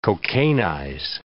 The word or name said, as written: cocainize